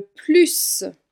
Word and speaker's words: plus: Quand le mot plus se trouve en fin de phrase, ou en tout cas sans aucun autre mot qui le suit, alors le S se prononce.